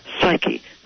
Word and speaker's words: psyche: And it's interesting, in ancient Greek, the word for butterfly was psyche in the original pronunciation, psyche, soul.